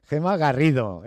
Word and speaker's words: Gema Garrido: Gema Garrido, esta vez lo digo bien, directora de la oficina de Fundación Quiero Trabajo en Madrid.